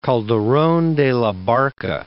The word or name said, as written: Calderon de la Barca